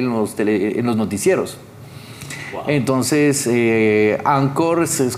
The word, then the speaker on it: Anchor: Sí, esta aplicación se dice Anchor, por si no lo pueden encontrar en Anchor, eh, se escribe así. Sí, es que eh, se pronuncia Anchor en inglés eh,